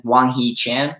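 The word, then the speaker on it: Hwang Hee-chan: Wang Hee Chan, is that it? – Yeah.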